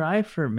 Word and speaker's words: Eifert: How do you pronounce it? – Tyler Eifert, man. – Eifert, Eifert with the Bengals.